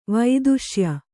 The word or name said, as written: ವೇತಸೇಯ